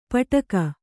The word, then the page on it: ಪಟಕ: paṭaka